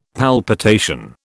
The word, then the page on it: palpitation: British English